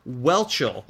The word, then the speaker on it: Whelchel: Is it Lisa Welchel? – Welchel. – I think I said Wenchel before. Lisa Welchel. – Mm-hmm. – Okay. Now, you may or may not know this. Lisa Welchel was actually on the TV series The Facts of Life.